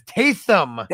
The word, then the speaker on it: Taysom: It's Taysom.